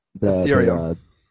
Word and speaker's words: Ethereum: So, bringing this conversation running closer to home plate here, I wanted to ask you about, I think I'm pronouncing it right, Ethereum, right? – Ethereum.